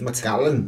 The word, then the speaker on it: Macallan: Och man kanske kan uttala det lite mer skotskt. – Macallan. – Ja, än så.